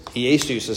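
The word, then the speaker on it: Iēsous: So this would be Iesus. – Iesus. – Iesus is how you say it in Greek.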